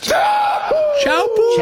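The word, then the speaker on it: Teahupo'o: And I believe it's pronounced Chowpoo. – Chowpoo.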